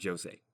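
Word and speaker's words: José: He's Portuguese, so it is pronounced Jose.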